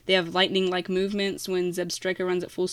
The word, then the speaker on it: Zebstrika: Is it Zebstrika? – Yeah. – That's You're saying it right.